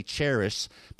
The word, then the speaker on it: charis: But with English Roman letters, we spell it C-H-A-R-I-S. Sometimes we say charis, but that's not how it's pronounced. It's more like charis.